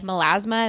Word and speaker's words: melasma: like skin pigmentation or like melasma.